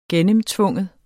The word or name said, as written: gennemtvinge